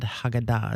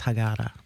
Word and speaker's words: Haggadah: I said it wrong. Mm-hmm. I said Haggadah, and she said, no, it's Haggadah. Okay, so I started reading about it, and I've been to a Seder dinner or two, mm-hmm.